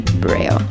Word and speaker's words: Breu: I think it's Brio, yes. – Brio. Mm-hmm. There we go. It's spelled B-R-E-U, but I have a cheat sheet. Yeah, Brio.